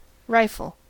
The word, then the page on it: rifle: American English